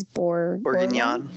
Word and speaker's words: Bourguignon: Bourguignon. – Bourguignon with crushed potatoes.